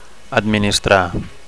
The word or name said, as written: administrar